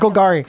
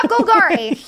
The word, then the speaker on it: Golgari: and Grav Gultari. I didn't say that right. Gultai. I'll have to ask. – Golgari. – Grav Golgari.